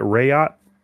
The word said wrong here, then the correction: Rakyat: Rakyat. – Rakyat.